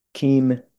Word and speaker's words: Keem: It was called Keem Beach or Kem Beach. I think it's technically pronounced Kem, but it's spelled K E E M. So not everybody knows the correct pronunciation. But Kem Beach was just so picturesque.